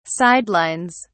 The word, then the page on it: sidelines: British English